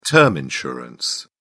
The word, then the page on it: term insurance: British English